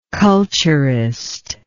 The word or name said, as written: culturist